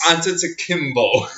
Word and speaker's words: Antetokounmpo: Antetokounmpo. – Antetokounmpo.